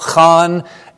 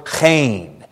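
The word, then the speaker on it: chen (חן): Can you hear that? Ta-chan, that second syllable, chan. A tachanunim is a plea for chen.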